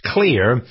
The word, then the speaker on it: Xlear: It's spelled X-L-E-A-R, but pronounced clear.